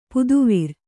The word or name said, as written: ಪುದುವಿರ್